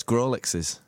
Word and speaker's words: Grawlixes: Are you going to try and pronounce it? Yeah, it's Grawlixes. Is it Grawlixes? Yeah, I'm saying Grawlixes.